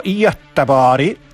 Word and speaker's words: Göteborg: Na przykład napisane Göteborg, ale po szwedzku zupełnie inaczej się to wymawia. Göteborg po szwedzku to Göteborg.